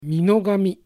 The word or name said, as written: Minogami